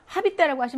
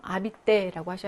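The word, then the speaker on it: habiter: 이거 아시는 발음이 안 나는 철자니까 하비떼라고 하시면 안 돼요. 아비떼라고 하셔야 됩니다.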